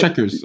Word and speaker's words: Checkers: C'est oui, checkers.